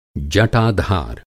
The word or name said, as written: जटाधर